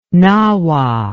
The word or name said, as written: nahua